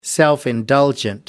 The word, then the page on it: self indulgent: American English